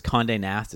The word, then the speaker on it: Condé Nast: Yeah, I was reading, because Condé Nast is how you say it, the mm-hmm.